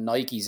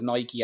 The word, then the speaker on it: Nike: When you think of Nike and you see his story, like, and he's selling Nikes out of the back. I called it Nike, but I remember now that in the book he says it's pronounced Nike.